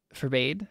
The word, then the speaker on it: forbade: I think it's pronounced forbade. – I gotta look this up. I write in scientific journals. I don't need to know how to spell things. That's what Google's for. – Bad pronunciation. – And I spell words that you guys have never heard of, so it's okay. It works itself out. – Yeah, it's for bad.